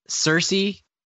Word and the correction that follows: Saoirse: Ronan. Did I say that right? I always feel like I'm going to butcher that one. – I think it's Saoirse.